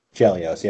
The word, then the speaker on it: Chelios: Chelios, is that how you say it? – Chelios, yep.